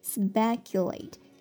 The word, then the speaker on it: speculate: S-P-E-C-U 读作 Q，最后是 late， speculate，它做动词表示推测，也有投机和深思的意思。 He speculated that he would succeed，这个例句意思是他推测，他猜想他会成功的。那有个短语叫做 speculate about，表示思考关于什么什么的东西。